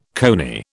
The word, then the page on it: coney: British English